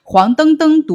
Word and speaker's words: 黄澄澄: 在现代汉语词典部分的黄澄澄读的是一声。